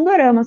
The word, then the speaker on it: dorama: Dorama é a palavra drama na pronúncia do japonês, né? – Porque uhum. eles pronunciam de um jeitinho ali que parece que eles falam dorama.